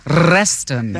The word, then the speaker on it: Reston: Oh, Reston.